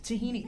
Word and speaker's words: tahini: Tahini sauce? – Tahini, right? – How do you spell that? – T-A-H-I-N-I, I think.